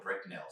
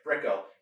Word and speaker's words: Brickell: How do we say that name wrong? – Brickell. – Right? – Yeah, okay. – Brickell. – So, yeah, my daughter picks on me a lot. I call it Bricknell. So, but Brickell is, which a lot of people call the Wall Street of the South.